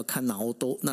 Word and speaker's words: 菅直人: 这个姓呢，菅那总理的姓一样是菅哈，呃，大家想一下，三一一的时候是菅直人，那那时候他是本身是民主党，然后现在这个是自民党叫菅义伟，虽然说他们两个日文发音是不一样哈，那那个菅直人他是叫看脑多，那然后这个就是菅义伟，就是他这因为他的姓，他的菅是叫伊念斯嘎哈，但是呢，我就发现他们遇到了跟三一一有时候有很像的一个一个状况，也就是说在。